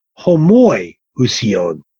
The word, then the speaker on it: homoiousion: And in Greek, there are two words that are very closely pronounced. One is homoousion, and the other is homoiousion. Homoousion means the same being the homoiousion means a like being.